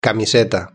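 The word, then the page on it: camiseta: Spain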